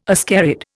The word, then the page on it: ascarid: Indian